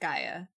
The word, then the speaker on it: Gaja: It's spelled G-A-J-A. And it is pronounced Gaia, even though it looks like Gaja.